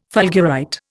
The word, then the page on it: fulgurite: Indian